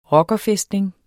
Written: rockerfæstning

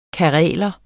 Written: kareler

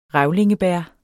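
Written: revlingebær

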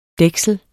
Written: dæksel

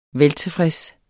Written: veltilfreds

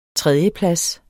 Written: tredjeplads

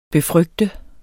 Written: befrygte

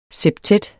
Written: septet

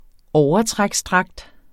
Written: overtræksdragt

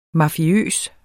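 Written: MAFIØST

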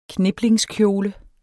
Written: kniplingskjole

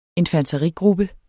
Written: infanterigruppe

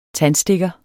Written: TANDSTIK

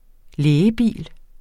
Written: lægebil

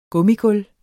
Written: gummigulv

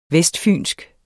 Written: vestfynsk

